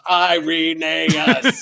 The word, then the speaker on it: Irenaeus: And he said, "How do we pronounce the name?" And it was hilarious. And we had one really bold, there was a really bold Irenaean scholar named Michael Slusser, mm-hmm. who's written a lot of articles on him. He stood up and he boldly pronounced, "It's Irenaeus."